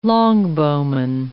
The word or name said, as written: longbowman